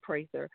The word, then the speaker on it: Prather: It's K-E-L-L-I, Prather. It sounds like Prather, but it's long A, Prather.